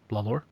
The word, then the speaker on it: Lawlor: Lawlor. – I didn't know if it was pronounced like Lawler, like Jerry the King Lawler. – That's how I'm pronounced.